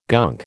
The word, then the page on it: gunk: American English